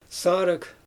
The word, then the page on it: saarɯɣ: Xyzyl